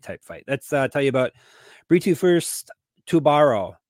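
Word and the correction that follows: Tubarão: Am I saying it wrong? It's a shark. – Tubau. – Tubau. Oh, that's right. It's Brazilian. Tubau. They have a weird relationship with R's in Brazil.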